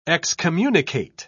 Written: excommunicate

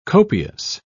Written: copious

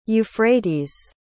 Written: euphrates

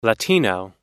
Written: latino

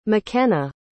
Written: makenna

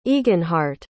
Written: eginhardt

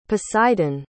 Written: poseidon

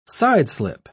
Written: sideslip

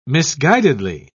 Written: misguidedly

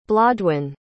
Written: blodwyn

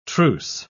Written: truce

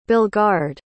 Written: belgard

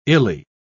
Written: illy

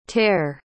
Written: ter

